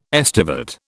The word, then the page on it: estivate: British English